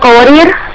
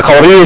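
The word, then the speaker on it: قواريرا: وانا بس قوارير الثانية قوارير الأولى الوقف عليها بإثبات الألف. – أيوه. – قوارير الثانية الألف محذوف وقفا ووصلا. – يعني أنطقها مثلا عند الوصف القوارير؟ – الأولى الأولى بإثبات الألف قوارير الثانية قوارير في الوقف والوصل. لكن الأولى وقفا مثبتة ووصلا تسقط الألف.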